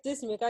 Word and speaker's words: Dismuke: At Faith Dismuke. I keep it real simple. So Faith Dismuke, D-I-S-M-U-K-E, because everybody spelled my last name wrong.